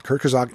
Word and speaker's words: Kurzgesagt: K U R Z G E S A G T. Kurzgesagt in a nutshell is what it's called. Kurzgesagt in a nutshell.